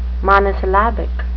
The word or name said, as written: monosyllabic